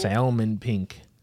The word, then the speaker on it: salmon: The L well, is silent. – In the fish, but in the color, it's salmon pink.